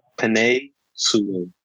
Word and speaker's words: Penei Sewell: Penei Sewell. I believe that's how you say it.